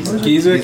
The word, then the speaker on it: Giza: For poking up over the horizon, I assume, is the Great Sphinx of Giza, which the cab is headed straight towards. Towards the massively overpopulated tourist area to see the Great Sphinx of Giza. Did I say Giza? – Giza? – Giza sounds right to me.